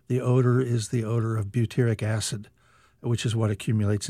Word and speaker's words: butyric: Butyric acid. – Butyric acid. – B-U-T-Y-R-I-C.